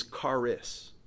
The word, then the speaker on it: charis: Our word for grace in the Greek is charis.